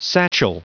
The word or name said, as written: satchel